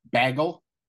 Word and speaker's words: bagel: Bagel? – Yeah, bagel is what you said, I think. You pronounced it like kegels. – Yeah? How should I? – Bagel. – Bagel. Like a long A?